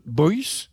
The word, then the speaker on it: Byagz: À l'instant, une composition d'Edouard Vesala à la prononciation compliquée parce que ça s'écrit B-Y-A-G-Z et Peter Held me dit ça se prononce Boyce, quelque chose comme ça.